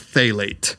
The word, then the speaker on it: phthalate: It's spelled really weird, but it's pronounced phthalate.